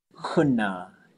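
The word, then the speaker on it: Hoonah: It's like, bro, it's always been called Huna. It's just people mispronounce it. That's all we're doing is fixing the mispronunciation.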